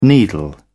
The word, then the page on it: needle: British English